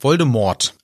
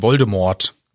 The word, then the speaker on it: Voldemort: Und da ist mir aufgefallen, in der deutschen, ja, in der deutschen Version, die Aussprache ist Voldemort. – Ach ja. – Und halt sehr deutlich Voldemort mhm.